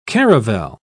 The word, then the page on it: caravel: British English